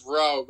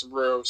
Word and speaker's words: Rue: I thought it was Roe, but I've heard Rue. It's Rue, so I guess you go. I thought Rue would be like R-O-O, and I don't know. I guess Roe could be. I think Roe would be either R-O-W-E or R-O-U-X, but who knows?